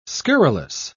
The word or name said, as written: scurrilous